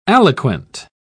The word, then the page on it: aliquant: British English